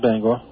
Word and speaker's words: Bangor: Yes, this is Bangor.